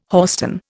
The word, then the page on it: hoarstone: Indian